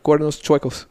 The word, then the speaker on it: Cuernos Chuecos: Uh, Cuernos Trecos, is that right? How do you say Crooked horn in Spanish? – Crooked horn? – Yeah. Oh yeah, yeah. Cuernos Chuecos.